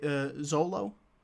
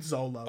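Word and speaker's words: Xolo: I think you pronounce it Zolo. Zolo Maraduena. – I'm not going to try, because I don't want to seem like an asshole. But... – my name is Xander. It's spelt with an X. His name is spelt with an X, so I'm going to go with Zolo.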